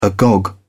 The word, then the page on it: agog: British English